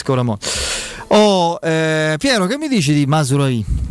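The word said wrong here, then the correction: Mazraoui: Masraoui, anzi, pronunciamolo bene.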